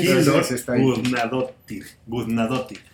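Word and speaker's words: Hildur Guðnadóttir: Eh, Hildur lo voy a pronunciar muy mal, Hildur Gudnad Es, e, es, islandesa, ¿no? mira, lo que sea no lo puedo pronunciar. – No, sí, Hildur Gudnadottir.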